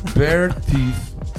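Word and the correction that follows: Beartooth: ale jeden. Oh. Jo. Ale promiň, řekl jsem to v množném čísle. Bear tooth.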